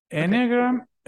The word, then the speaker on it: Enneagram: Enneagram? – Okay.